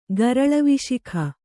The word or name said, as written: ಗರಳವಿಶಿಖ